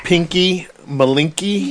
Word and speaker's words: Pinky Malinky: Pinky Malinky? – Yeah.